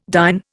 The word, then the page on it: dyn: Indian